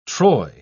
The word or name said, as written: troy